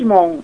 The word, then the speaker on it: Mon: Eu pronunciei seu sobrenome corretamente? – Como Beatriz Mon, isso mesmo.